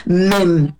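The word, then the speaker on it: mem (מם): Así es que eso significa el número 40 encerrado en esta letra que se pronuncia, yo espero decirla bien, mem. Así como se escucha.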